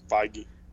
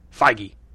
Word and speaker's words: Feige: That was conferred by Kevin, I'm going to mispronounce his name, Feige. Whatever. Feige. He was confirmed. What is it? – Feige. – Feige. Sorry about that, Kevin Feige.